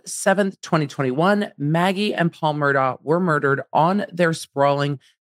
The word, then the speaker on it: Murdaugh: Look, it's spelled Murdaugh. It's hard to pronounce it Murdaugh.